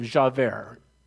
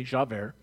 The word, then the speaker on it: Javert: Until one day, he was being pursued by this wonderful cop by the name of Javert. That's how you pronounce it. Say it with me, Javert.